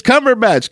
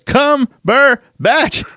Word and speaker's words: Cumberbatch: Benedict Cumberbatch, he actually pops up and he corrects everybody. He says, it's Cumberbatch. Cumberbatch. It's three syllables.